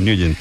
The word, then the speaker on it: Nugent: Pero la pronunciación correcta es Nugent. – Te fuiste a Washington y descubriste que era Nugent.